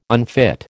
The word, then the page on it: unfit: American English